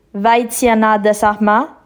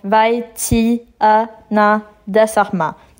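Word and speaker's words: Vaithyanathasarma: Puis mon nom de famille c'est Vaitiana Dasarma, donc Vaitiana Dasarma, Donc Vaitiana Dasarma.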